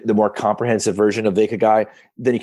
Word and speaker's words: ikigai: How would you, am I pronouncing it right? I looked at your eyes and it seemed like. – Yeah, no, it's Ikigai. – What is it? – It's kind of, well, the model I like is really layered. So for the simplest version of it, it's important to one, spell it correctly. It's I-K-I-G-A-I, Ikigai. It's Japanese.